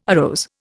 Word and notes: arose: Indian

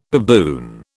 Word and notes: baboon: British English

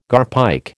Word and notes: garpike: American English

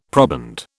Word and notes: proband: British English

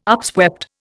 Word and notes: upswept: Indian

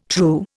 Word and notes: true: Indian

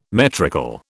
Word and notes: metrical: British English

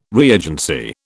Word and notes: reagency: British English

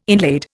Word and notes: inlaid: Indian

